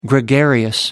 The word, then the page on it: gregarious: American English